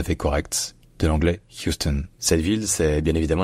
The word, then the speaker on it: Houston: Puis le OU se dit you, you. Et enfin, le stone en fait se dit stone, c'est-à-dire que le O devient un schwa, une voyelle neutre, qui va être à peine prononcée, parce que l'accent tonique est sur la première syllabe. Donc c'est Houston, Houston.